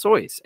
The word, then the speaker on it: Seuss: However, it's pronounced Soyce. And him saying in a poem that it's pronounced Soyce is completely valid.